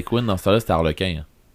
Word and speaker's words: Harlequin: C'est que Harlequin en anglais, ça se prononce Harlequin.